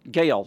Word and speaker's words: Gael: It's Gale. Is that how he pronounces it? Gail. Gail. G A E L.